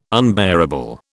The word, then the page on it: unbearable: British English